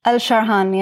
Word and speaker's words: Alsharhan: Alsharhan, yeah.